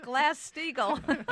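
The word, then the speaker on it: Glass-Steagall: I think it rhymes with Glass Steagall.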